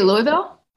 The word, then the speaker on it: Louisville: Did you say Louisville? – Louisville. – I heard you say it correctly.